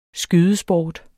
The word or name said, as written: skydesport